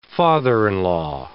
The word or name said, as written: father-in-law